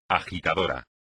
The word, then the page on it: agitador: espagnol